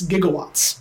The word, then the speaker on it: gigawatts: Gigawatts is actually mispronounced, by the way. It's gigawatts. – Gigawatts. – It's a okay. hard G.